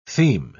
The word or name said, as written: theme